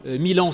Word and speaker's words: Milan: Milan, c'est une ville en Italie, mais ça se prononce comme 1000 ans.